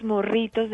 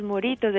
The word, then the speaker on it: morritos: morritos de bizcocho, pero no pronunciaba la doble R. – Ah, no podía por suizo, entonces de ahí. – Entonces él decía que vendía muchos moritos de bizcocho, entonces las niñas creían que era que eso se llamaba morito, estoy hablando de las niñas, las vendedoras.